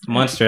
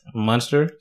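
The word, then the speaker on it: Muenster: So how do you M-U-N. – How do you pronounce the cheese? – Munster. – Same thing. Okay. – I buy Munster cheese. M-U-E-N-S-T-E-R. I know it.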